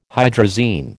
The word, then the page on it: hydrazine: American English